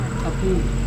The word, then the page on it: kapung: Serewen